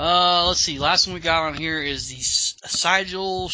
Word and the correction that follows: Siegel: Schuster versus DC. Now this one. – Siegel. – Excuse me. I always say that wrong.